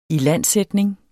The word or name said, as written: ilandsætning